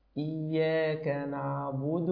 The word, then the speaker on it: إياك: كذلك رقق جيدا كلمة إياك وإياك وشدد الياء بهذه الطريقة إياك نعبد وإياك نستعين. فتفضل مشكورا يا أخي الحبيب وجزاك الله خيرا.